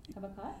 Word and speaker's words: Habakkuk: Habakkuk.